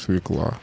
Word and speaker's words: свекла: У меня вот свой словарь тут лежит, написанный нами, где написано черным по белому свекла. То есть со вчерашнего дня это слово произносится не иначе, как свекла.